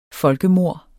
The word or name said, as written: folkemord